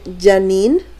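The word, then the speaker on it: Janeane: Eh, sí, o sea, como Janine, pero se escribe como Haneane. Haneane.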